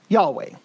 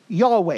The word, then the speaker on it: Yahweh: Y-H-W-H. Yahweh. You're like, wait a second. Yeah, in ancient Hebrew, they didn't have vowels. So you would have Yahweh. It would have the four consonants, Y-H-W-H, Yahweh.